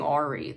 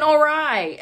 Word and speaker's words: awry: I came to learn that this whole time I have been mispronouncing the word awry. Like, I've been saying Ari this whole time. Things have been going Ari. That's what I've been saying this whole time. When it's been awry.